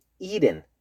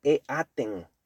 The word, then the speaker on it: eaten: Eat es el presente, ate es el pasado y eaten se escribe e-aten, eaten. Pero queremos decir eaten, así como la T de fiesta, party, eaten, party, eaten, eaten.